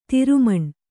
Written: ತಿರುಮಣ್ಣು

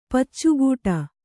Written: ಪಚ್ಚುಗೂಟ

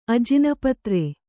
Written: ಅಜಿನಪತ್ರೆ

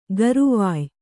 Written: ಗರವೊಯ್

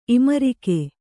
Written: ಇಮರಿಕೆ

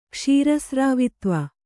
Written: ಕ್ಷೀರಸ್ರಾವಿತ್ವ

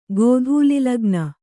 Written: ಗೋಧೂಲಿಲಗ್ನ

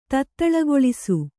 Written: ತತ್ತಳಗೊಳಿಸು

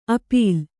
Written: ಅಪೀಲ್

